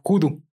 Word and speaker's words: Hoodoo: de una lengua africana llamada judú. ¿Ok? ¿Con G? Eh, no, es donde que se escribe judú, se escribe H-O-O-D-O-O. Como vudú, güey, pero con H. Ajá, exactamente. Y Hoodoo se escribe H-U-D-U.